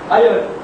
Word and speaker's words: iron: The classification of iron. I hope you are also pronouncing iron only, not iron.